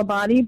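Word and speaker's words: Abbate: Everything is under my name, which is Michelle Abadi, but it's spelled with a one.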